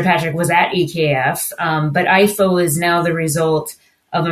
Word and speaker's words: EIFO: He is a senior vice president at Denmark's Export and Investment Fund, which is pronounced EIFO, with a special focus on wind energy and power to X.